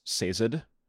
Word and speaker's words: Sazed: So I believe canonically in the audiobooks, which I believe are, according to Brandon, the canonical pronunciations, I believe it is Sazed.